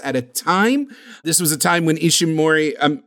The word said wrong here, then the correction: Ishinomori: I already mispronounced his name. That's fine. Ishinomori had been accepting work left and right,